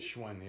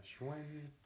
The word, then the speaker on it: Schwinn: A computer building kit, a flashlight, a Schwinn bicycle. Damn. Am I saying that right? – Schwinn, yeah. Schwinn.